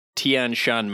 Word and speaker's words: Tian Shan: It started in the Tian Shan mountain range, which is in northwest China.